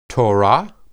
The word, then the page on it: torah: hebrew